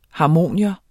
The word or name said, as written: HARMONIUMS